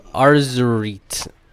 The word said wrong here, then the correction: Azerite: I can't pronounce it. Azerite.